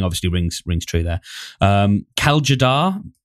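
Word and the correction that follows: Tjader: probably butchering that pronunciation. – Jader, I think is how it's typically pronounced. Kaljader.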